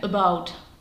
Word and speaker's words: about: Víme, že správně se tam píše a na začátku, about, ale při výslovnosti je ten stres až na tu druhou slabiku, na to about. To znamená, že samostatně se nám v mozku odehraje takový proces, při kterém si řekneme, první slabika není ve stresu, to znamená, že není třeba dávat nějaký důraz na tu samohlásku, tak tam fláknu schwa. Takže vznikne about.